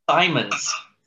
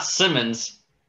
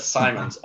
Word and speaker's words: Simons: Simons. Not Simmons, but Simons.